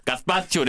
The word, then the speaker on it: gazpacho: gazpacho, det är så man uttalar det.